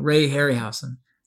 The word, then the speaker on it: Ray Harryhausen: Ray Harryhausen.